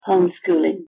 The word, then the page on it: homeschooling: English